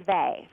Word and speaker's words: Weil: I think it's, is it Simone Weil? – Yeah, it's actually pronounced Vey for some reason.